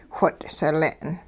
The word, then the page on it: whot' sile'n: hupa